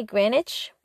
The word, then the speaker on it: Greenwich: It's actually Greenwich Village. You're not supposed to pronounce the W in Greenwich, but people from out of town always say it wrong.